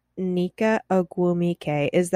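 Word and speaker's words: Nneka Ogwumike: Now, you know, a week ago or more now, I guess, there was an op-ed published by Sue Bird and Nika Ogwumike. Did I say her name right? – That's how I would say it.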